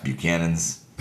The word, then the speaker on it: Buchanan's: But in the valley, it's Buchanan's. But it's pronounced Buchanan's.